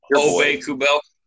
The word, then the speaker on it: Ove Kubel: and they know he doesn't play like Kubel or Kudel. I'm butchering his fucking name. – Ove Kubel.